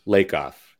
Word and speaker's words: Lakoff: Lakoff.